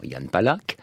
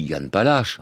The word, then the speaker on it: Jan Palach: Il semble que la vraie prononciation, euh, chimiquement pure, pour le coup, ce soit Yann Palach, avec ce re qui ressemble à la, rota, espagnole, ou au ch allemand.